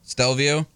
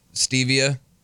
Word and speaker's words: Stelvio: Nick, is that how you say it? – What? – Stelvio? – Yeah. – Oh, I need to correct a lot of things. – You've been saying what? – Stevia.